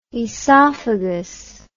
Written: oesophagus

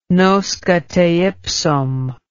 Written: nosce te ipsum

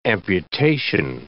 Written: amputation

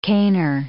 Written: caner

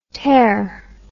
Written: terr

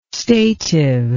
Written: stative

